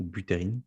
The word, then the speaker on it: Buterin: Donc Ethereum, ça a été créé le 30 juillet 2015 euh, exactement par un programmeur qui s'appelle euh, Vitalik euh, Buterin, ou Buterin si on le prononce bien.